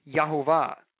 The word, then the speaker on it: Jehovah: and you get Yahovah, or in English Jehovah. Okay, so that's where the name comes from. So it's really a made-up word. Um, it's uh, sort of an anglicized way of pronouncing the divine name.